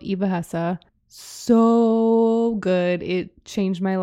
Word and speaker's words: Eva Hesse: People always say her name differently. It's like Eva Hessa or Eva Hess or Eva Hess, but it is from her documentary, Eva Hesse.